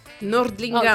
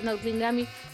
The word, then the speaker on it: Nordlingami: E, I ten konflikt Nilfgaardu z Nordlingami, z jak się wymawia? Nordlingami. O, dziękuję, Nordlingami.